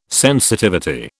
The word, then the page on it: sensitivity: British English